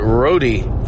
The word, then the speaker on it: Rode: But the Road, I knew Paul Road, R-O-D-E, but he pronounced it Rody for some reason.